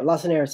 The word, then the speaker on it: Lacenaire: Tell me about this Lacenaire and how Lacenaire. He was influenced by him. I apologize for the pronunciation. – Yes, yeah, Lacenaire. So my book tells two stories.